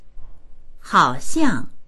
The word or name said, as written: hao3xiang4